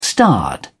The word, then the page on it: starred: British English